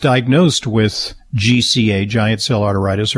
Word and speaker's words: arteritis: It's like, it sounds like I'm mispronouncing arthritis, but I'm deliberately saying arteritis because it's an itis. It's an inflammation of the arteries.